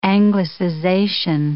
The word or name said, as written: anglicization